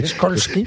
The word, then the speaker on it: Skolsky: No, señor, no es SH, es SKOLSKY. – Impronunciable. – A la señora. – Raskolsky. – ¿Cómo se pronuncia? Porque es polaco. ¿Cómo se pronunciaría en polaco Skolsky? – Skolsky. Como tú lo has dicho.